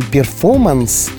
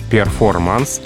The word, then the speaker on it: перформанс: По аналогии с английским перформанс, то есть R оттуда выпадает, или все-таки в русском это R должно звучать? – Это слово попало уже в словаре русского языка, в орфографический словарь академический, где оно действительно пишется с буквой R, перформанс. И, в общем-то, нет никаких оснований этот звук в речи выпускать, потому что все-таки мы произносим не по-английски, а по-русски. Поэтому перформанс — это слово, да, заимствованное из английского языка.